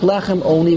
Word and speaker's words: Lechem Oni: But when you read it, there's no difference in the pronunciation between an Ayin and an Aleph. So it sounds like Lechem Oni with an Aleph.